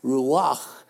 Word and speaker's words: ruach: And the Spirit, the word Spirit, is the Hebrew word ruach. And if you say that, ruach, there's almost an exhale. There's a breath that's implied that comes from the pronunciation of that word.